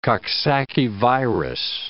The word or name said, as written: Coxsackie virus